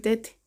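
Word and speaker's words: Te (태): Que de hecho, fíjate, tuve muchos años esa duda de por qué le dicen Tete. – Tete. – Y luego descubrí que es porque no es Tae, si en coreano es Taehyung, algo así, ¿no? Pero no se pronuncia Tae, se pronuncia Te.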